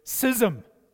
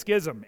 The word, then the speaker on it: schism: The word for divisions is the word schism, or as Martin Lloyd-Jones pronounced it, schism. So, I mean, who's to argue with him? But I think it's schism. Anyways, these are divisions, and you've maybe heard about schisms in the church, splits, church divisions.